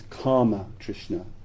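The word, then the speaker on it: kāma tṛṣṇā: It's interesting that the word here is karma trishna, K long A-M-A, karma trishna.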